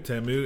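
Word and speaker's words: Ta'amu: Jordan, am I saying this right? Jordan Tamu. – Tamu, yeah.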